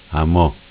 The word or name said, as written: A mo